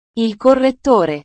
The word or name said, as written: il correttore